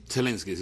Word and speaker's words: Zelensky: ¿Se pronuncia así? – Zelensky, sí.